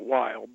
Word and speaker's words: Weill: Am I saying it right? Weill Medical College? Or is it? – I think they pronounce it Weill. But Weill, all right. – Close enough.